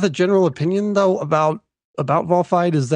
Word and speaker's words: Volfied: Make sure that we understand that it's pronounced Volfied.